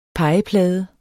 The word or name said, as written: pegeplade